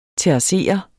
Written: terrassere

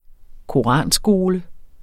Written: koranskole

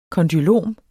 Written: kondylom

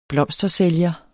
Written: blomstersælger

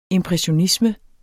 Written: impressionisme

